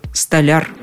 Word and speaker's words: столяр: Но в любом случае ударение в слове именно такое – столяр.